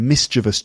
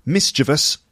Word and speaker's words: mischievous: And it's apparently the correct pronunciation of it is mischievous, but you might often hear people saying mischievous, because that's how the word looks. We also say mischief, so people assume it's mischievous because it sounds like the word mischief and it looks like the way mischievous or mischievous is spelt.